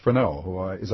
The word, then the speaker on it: Freneau: Benjamin Franklin, Thomas Jefferson, Ethan Allen, Thomas Paine, Elihu Palmer, and Philip Freneau. Am I saying his name right? – Absolutely.